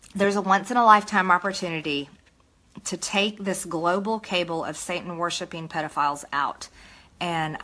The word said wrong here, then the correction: cabal: A global cabal, I think it's pronounced, of Satan-worshipping pedophiles.